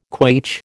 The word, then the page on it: quaich: American English